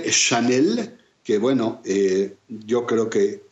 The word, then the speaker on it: Chanel: y que Chanel no es más que una derivación de Hannah, que al pasar a Francia, la CH se pronuncia precisamente como lo están ustedes escuchando.